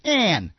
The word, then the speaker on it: Ayn: It's Anne. Her name's Anne.